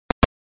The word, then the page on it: relaciones: ispanyolca